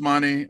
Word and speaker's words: Mane: So, so yeah, at comedian James Mane, it's spelled M-A-N-E, my last name, on Twitter, Instagram, Facebook.